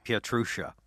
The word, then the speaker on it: Pietrusza: say it. Say it for me. Pietrusza. Pietrusza. Uh, well, I'm going to call David from now on because it turns out I have been mispronouncing David's last name in my head and on this podcast.